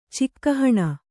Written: ಚಿಕ್ಕಹಣ

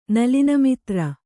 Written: ನಲಿನಮಿತ್ರ

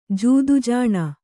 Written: ಜೂದುಜಾಣ

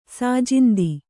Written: ಸಾಜಿಂದಿ

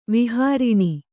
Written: ವಿಹಾರಿಣಿ

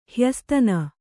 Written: ಹ್ಯಸ್ತನ

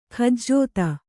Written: ಕುಜಾತೆ

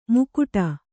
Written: ಮುಕುಟ

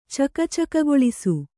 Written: ಚಕಚಕಗೊಳಿಸು

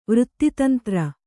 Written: ವೃತ್ತಿತಂತ್ರ

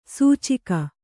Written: ಸೂಚಕ

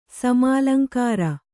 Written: ಸಮಾಲಂಕಾರ

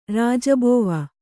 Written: ರಾಜಬೋವ

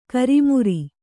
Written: ಕರ್ಮೀರ

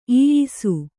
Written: ಈಯಿಸು

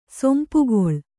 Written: ಸೊಂಪುಗೊಳ್

